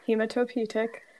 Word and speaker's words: hematopoietic: Hematopoietic, is that correct? – I'm going to give you it. – Cool, great. Hematopoietic.